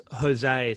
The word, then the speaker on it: Goza: I believe it's pronounced Jose. It's a soft G.